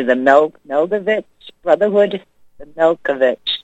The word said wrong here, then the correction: Melchizedek: Maybe you can help me out with pronouncing that. The Melodek, something like that. – Melchizedek? – Yeah, that's it. You said it.